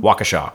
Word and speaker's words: Waukesha: Waukesha.